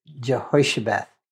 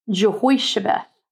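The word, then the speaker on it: Jehoshabeath: They are, and so when you look at it in Scripture, it uh, it's I read it as Jehoshaba, and you say it's pronounced how? – It's Jehoshabeth. – Jehoshabeth.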